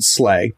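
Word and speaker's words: SLA: And the first one I want to learn about, Anthony, that you mentioned was Slay, and it's S-L-A. Mm-hmm. My understanding is you pronounce it Slay.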